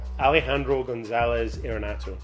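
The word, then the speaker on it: Alejandro González Iñárritu: Alejandro Gonzalez Iñárritu.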